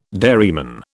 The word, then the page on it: dairyman: British English